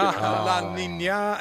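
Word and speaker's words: La Niña: La Nina and El Nino, I was told this morning.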